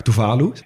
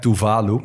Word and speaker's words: Tuvalu: Het is dus wel echt Tuvalu met, uit, eh, met hmm. – klemtoon op de tweede lettergreep.